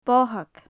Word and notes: bothaig: gaelic

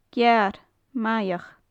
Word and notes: gearr maigheach: gaelic

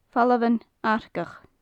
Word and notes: famhalan arcach: gaelic

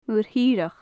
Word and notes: muir thireach: gaelic

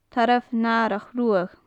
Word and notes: tarbh nathrach ruadh: gaelic

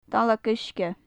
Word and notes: dallag uisge: gaelic

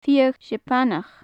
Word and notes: fiadh seapanach: gaelic

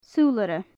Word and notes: sulaire: gaelic

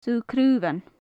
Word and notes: subh-craobhan: gaelic

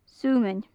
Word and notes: sumainn: gaelic

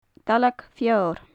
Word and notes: dallag feoir: gaelic

